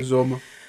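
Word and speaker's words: Uzomah: I think it's Uzama. CJ Uzama. Yeah. Am I saying that right? – Uzama.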